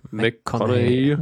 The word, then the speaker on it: McConaughey: Ähm, ja, also das ist zum Beispiel sehr bemerkenswert, aber auch die, der Hauptdarsteller Matthew, oh Gott, wie spricht man den aus? Äh, McConaughey. Matthew McConaughey? – Ja.